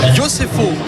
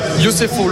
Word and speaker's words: Yosefo: Xander Yosefo, c'est comme ça qu'on prononce, lui-même. – Yosefo,